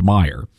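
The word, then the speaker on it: Meijer: It's spelled like Major, but it's pronounced Meyer.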